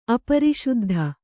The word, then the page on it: ಅಪರಿಶುದ್ಧ: apariśuddha